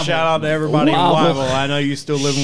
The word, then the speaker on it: Whiteville: We have a place in North Carolina called Whiteville, but we pronounce it Wavel.